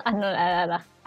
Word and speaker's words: anonadada: Me he quedado anonadada. anodada, ¿Cómo se dice? Anodada. Anona. – Anonadada.